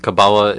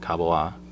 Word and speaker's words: Kabbalah: Kabbalah is a English pronunciation. It's also a Yiddish pronunciation, and there are lots of people in Israel and America and Europe and all over the world who pronounce it Kabbalah all the time, who practice it. And there are also people who pronounce it Kabbalah, which is the Hebrew Israeli accent.